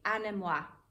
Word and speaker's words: anemoi: it comes from the Greek anemoi, A-N-E-M-O-I, anemoi, I think that's how you say it.